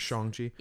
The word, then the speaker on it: Shang-Chi: Shang-Chi. We finally got confirmation of the pronunciation a couple weeks back. It's yes. Shang-Chi.